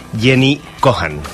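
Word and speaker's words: Jenji Kohan: Cada temporada va a tener 13 capítulos y es de la creadora de Wits, Jenny Cohan, que no sé si lo he pronunciado bien, pero bueno. – Has pronunciado muy bien.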